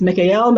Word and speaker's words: Mikael: So this pronunciation is Mikael. And we hear that L sound vibration.